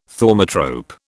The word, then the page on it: thaumatrope: British English